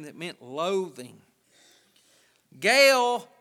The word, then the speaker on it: Gaal: Now, his name is spelt G-A-A-L. But Alexander Scorby said Gaal. And so that's my authority on how to pronounce his name. If I ever don't know how to pronounce anybody's name, I go and listen to how Alexander Scorby said it. That's a pretty good reference point. He called him Gaal. And I looked and looked. How would you pronounce that? But it's pronounced Gaal. But his attitude's gall.